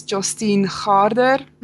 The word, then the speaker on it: Jostein Gaarder: It's pronounced as Jostein Gaarder.